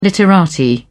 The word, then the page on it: literati: British English